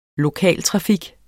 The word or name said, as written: lokaltrafik